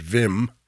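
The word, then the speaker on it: Willem: The way you pronounce his first name is Wim, mm-hmm. although it's W-I-L-L-E-M, Zurdig.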